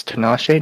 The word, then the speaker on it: Tinashe: 2-1, and just saying it's Tinashe, not Tinashe, not...